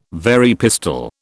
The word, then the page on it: Very pistol: British English